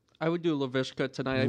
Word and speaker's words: Laviska: Laviska. – Laviska.